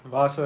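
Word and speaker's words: Vaasa: I think it's Vasa. – I think it's right. I played in Finland back in the day. Vasa.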